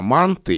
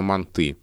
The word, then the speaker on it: манты: Это слово в разных словарях русского языка зафиксировано с разным ударением, причем мне неизвестен ни один словарь, который бы говорил, что правильные манты и манты. Либо в словаре только манты, без вариантов, либо в словаре только манты, без вариантов.